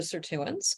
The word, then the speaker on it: sirtuin: Also, tell us a little bit about sirtuins. I have to pronounce that correctly. – Sirtuin. Yes.